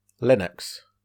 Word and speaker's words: Linux: Linux.